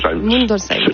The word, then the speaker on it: Mundolsheim: J'ai bien prononcé. – Mundolsheim. Oui, c'est Mundolsheim. C'est bien cela.